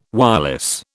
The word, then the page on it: wireless: British English